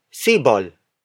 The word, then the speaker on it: sibol: This lesson is brought to you by Seaball. Seaball. Seaball. Seaball is spelled as S-I-B-O-L.